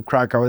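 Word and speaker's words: Kraków: So we are at WordCamp Europe, which is in Krakow, or Krakow, I don't know how. – Krakow. – Krakow. Thank you, that was good.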